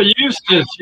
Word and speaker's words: Eustis: Eustis. Is that how you pronounce it? Eustis, Florida? – Oh, Eustis. Yeah, I'll be there in a few days.